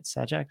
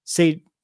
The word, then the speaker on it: Sajak: Sajak? – Sajak.